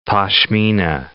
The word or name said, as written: pashmina